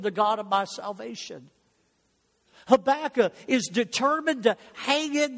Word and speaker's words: Habakkuk: Listen to the words of Habakkuk, or even the newer pronunciation, some of it is Habakkuk, so whichever you like, great. It was Hebrew, nobody knows how to say it anyway.